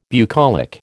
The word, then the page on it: bucolic: American English